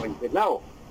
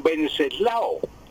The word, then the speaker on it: Wenceslao: Pero esa pronunciación es en inglés, don Wenceslao. En español sería don Venceslao, don Venceslao.